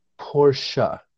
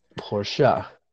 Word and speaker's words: Porsche: It is pronounced Porsche. – Yeah. – It's not French. It is Austrian. Porsche.